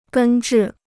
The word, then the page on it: 根治: gēn zhì